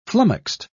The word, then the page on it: flummoxed: British English